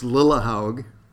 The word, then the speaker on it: Lillehaug: It's Lillehaug, and that problem has dogged me ever since the playgrounds back in kindergarten. – um, my name has been pronounced every conceivable way. It, um, I, we act, we took a trip recently to Iceland, my family and I, took a trip to Iceland, and everybody could pronounce my name because it is Scandinavian.